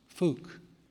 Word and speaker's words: Phuc: Her last name is spelled P-H-U-C. It's pronounced Fook.